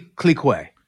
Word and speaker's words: clique: But it's actually Clique. That's how you spell. That's Clique. How you're supposed to pronounce it.